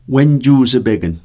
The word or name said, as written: wenjusipekn